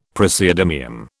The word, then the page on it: praseodymium: British English